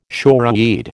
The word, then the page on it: shoreweed: American English